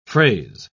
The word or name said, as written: phrase